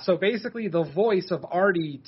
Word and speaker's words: Aethari: Artie spelled A-E-T-H-A-R-I, but still pronounced Artie.